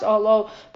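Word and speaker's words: Ohalo (אהלה): But the actual letter is read as Ohala. It doesn't. It's not Aleph Hey Lamed Vav Ohalo. It's pronounced Ohalo, but it's written Aleph Hey Lamed Hey, which reads Ohala.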